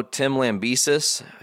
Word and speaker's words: Tim Lambesis: For those of you who don't know, Tim Lambesis, I said it right? – Yes.